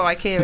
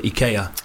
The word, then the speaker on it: IKEA: I'm pretty sure IKEA is Swedish.